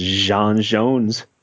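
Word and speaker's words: J'onn J'onzz: And we did finally get confirmation that his name is pronounced John Jones. – That's correct. So everybody else needs to back up. It's like Ra's al Ghul, right? – Yeah.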